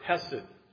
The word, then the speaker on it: hesed: I think I sent out a little thing on Slack some time ago about the word hesed, right? And to say it properly, it has that H has to catch in the back of your throat.